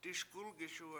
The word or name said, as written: diSkUlgiSuwe7